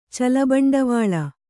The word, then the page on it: ಚಲಬಂಡವಾಳ: Kannada